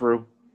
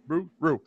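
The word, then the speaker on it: Roux: How is it pronounced? – What do you think? Rogue? – It's Rue. – Rue.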